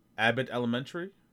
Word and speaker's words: Abbott Elementary: Abbott Elementary.